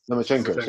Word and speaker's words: Lomachenko: So, Lokomenko, I don't know how to pronounce his name. Lokomenko? – Lomachenko.